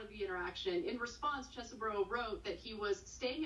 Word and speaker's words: Chesebro: And I'm sure Chesebro is the way to pronounce it, but anybody who decides to represent Trump, I'd like to pronounce his name Cheesy Bro going forward.